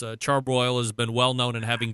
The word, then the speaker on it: Char-Broil: I am a well-known, longtime, staunch critic of Charbroil, and I'm saying it right this time, not like we did the last time.